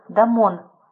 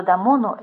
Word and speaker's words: Дамон: Учителем музыки у Перикла был, как сообщает большинство наших источников, Дамон. Первый слог этого имени, говорят, следует произносить кратко.